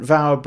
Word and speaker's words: Fawr: If you search Nant Vauer Brews on TikTok, and remember, Nant Vauer is spelt Nant and then Fawr. It's a Welsh word, so it sounds like a V, but it's spelt with an F.